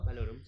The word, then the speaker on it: Valorum: Bueno, hay quilombo con el canciller supremo Valorum. No sé si está bien pronunciado. – Valorum.